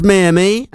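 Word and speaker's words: Mia Mia: I'm going to go Mia Me. What do you reckon? – What, how's it spelled? – M-I-A, Mia, yeah. And then M-I. – Yeah, Mia Me. – Yeah? – Yeah. – Okay. That was Mia Me, that's how you pronounce it, with a brand new single, Miss Good Enough For Now.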